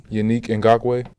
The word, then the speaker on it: Yannick Ngakoue: Yannick Ngakoue.